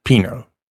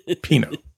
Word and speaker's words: Pino: Yeah, Lisa J. Pino. – We think. – Yeah. Like P-I-N-O. – Yeah, yeah, not with a T. – Pino.